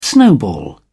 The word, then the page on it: snowball: British English